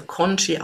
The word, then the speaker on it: Congee: Man schreibt es mit C wie Cäsar, Otto, Nordpol, Gustav und zweimal Emil, also Congee ausgesprochen.